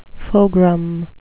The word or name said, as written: fogram